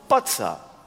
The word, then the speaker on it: Bozza: Sein Name klingt französisch, Eugène Botza. Sein Vater war aber Italiener und der hieß noch Bozza. Pizza, Nizza, Bozza. Ja. Aber in Frankreich betont man alles auf der letzten Silbe und deswegen wurde aus Herrn Bozza in Italien, in Nizza, Herr Bozza.